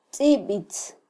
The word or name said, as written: tzibitz